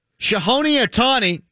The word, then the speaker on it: Shohei Ohtani: Shohei Ohtani, did I say that right, Tom? – Nailed it.